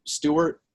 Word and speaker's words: Sturt: So my last name is S-T-U-R-T, but it's pronounced like Stuart, like if there were an A, but there is no A.